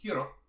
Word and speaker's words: Hiro: but you don't really she's the only one who pronounces Hero's name as with its Japanese pronunciation. – Yeah, Hiro. Hmm. Hiro.